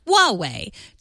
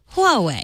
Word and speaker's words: Huawei: He says the H in Huawei, Chinese hardware manufacturer, should actually be pronounced. I've noticed that you regularly say it like Huawei. Just add a little push of air in front of it to make that H sound. Huawei.